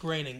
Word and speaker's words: Groening: I thought it was Groening. No. – I think it's pronounced Groening. – Okay.